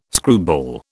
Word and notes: screwball: British English

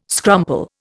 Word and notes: scrumple: Indian

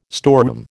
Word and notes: storeroom: American English

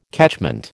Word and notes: catchment: American English

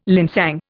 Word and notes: linsang: Indian

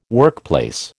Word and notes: workplace: American English

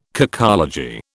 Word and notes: cacology: British English

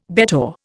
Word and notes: bettor: Indian

